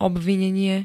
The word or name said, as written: obvinenie